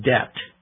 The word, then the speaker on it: debt: When Lincoln was 23 years old, he bought a small store, but it failed, and he fell into debt. When we say someone is in debt, D-E-B-T, we don't pronounce the B, we mean that he owed other people money.